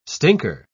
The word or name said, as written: stinker